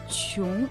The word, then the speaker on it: 穷: Слово «чун» произносится также во втором тоне.